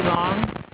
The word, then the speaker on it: Long: Cindy Long.